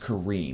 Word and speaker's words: Karim: It's spelled K-A-R-I-M. – I was about to say. – So I'm pretty sure it's pronounced Kareem.